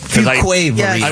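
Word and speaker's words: Fuquay-Varina: Someone from Chapel Hill is helping us with the pronunciation of that place. – It's Fuque Varina, right? – Fuque. – I was just afraid to say it. – Fuque Varina.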